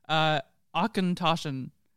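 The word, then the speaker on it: Auchentoshan: And the reason why we knew sort of how to say it is because on the box they give a nice pronunciation, Achentoschen, which just sounds German.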